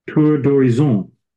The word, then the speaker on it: Tour d'horizon: Tour d'horizon.